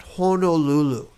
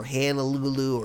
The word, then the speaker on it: Honolulu: So Honolulu, and by the way, you pronounce Honolulu like that, Honolulu, not Honolulu or Hanalulu or Hananalulu. I have heard of it. It's Honolulu.